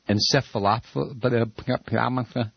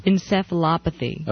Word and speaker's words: encephalopathy: Encephalopathy. Now, you have to open your mic so we can hear you. – Encephalopathy.